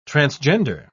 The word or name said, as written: transgender